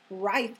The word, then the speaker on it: Reith: Reith. It's spelled R-E-I-T-H. It's like Keith, but with an R, but that pronunciation right. will mess you up.